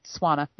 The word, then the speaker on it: Tswana: Tswana, I think. Tswana, that's it. Pronunciation.